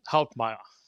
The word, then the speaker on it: Hauptmeier: You got to have a German accent in order to pronounce it right. It's Hauptmeier.